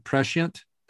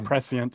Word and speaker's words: prescient: And it was incredibly, I don't know how to pronounce this word, prescient. – Yeah, that's how I say it. – Okay. – Or prescient sometimes.